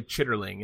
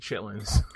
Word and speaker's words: chitterling: But yeah, no, I've never heard anyone say chitterling unless they read it and they didn't know what the fuck they were saying, like you just did. So it's not how it's pronounced.